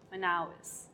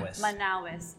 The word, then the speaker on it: Manaois: My real surname is Celina Manaus. Manaus. Can you pronounce it? – Can I? – Manaus. M-A-N-A-O-I-S.